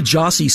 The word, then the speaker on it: Gyasi: We're going to listen to Jossie, spelled G-Y-A-S-I, probably hence the title of the album.